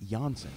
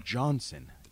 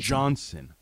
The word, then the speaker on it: Johnson: Janssen going out. – Yeah, Jacob Jan. It's not Janssen. It's Johnson. Jacob. – Jacob Johnson. – Johnson. Soft J, hard J.